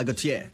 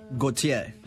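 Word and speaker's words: Gotye: Gautier. Gautier. You say it like a French Canadian.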